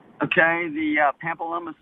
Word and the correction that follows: Pamplona: Spain question? – Yes, sir. Here's the question. – How do you spell that town now? – It's Pamplona. – Pampeluna? – Pamplona. – Pamplona. – I think I added an extra syllable? – Vowel in there.